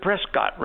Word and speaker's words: Prescott: You know, it's P R E S C O T T. Okay, you pronounce it Prescott, right? Prescott. That's the way they say it down there.